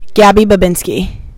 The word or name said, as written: Gabby Babinski